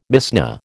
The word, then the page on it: Mishnah: American English